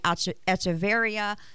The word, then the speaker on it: Echeverria: I'm here with Josh Newby and Emily Echeverria. Did I say that right, Emily? – Nailed. You're doing great. Echeverria, if you want to roll the R.